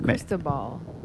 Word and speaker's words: Cristobal: How do you say it? – Cristobal? – That's you? – It's Cristobal.